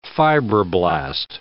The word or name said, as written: fibroblast